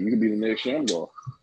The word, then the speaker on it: Shamgar: Shamgar.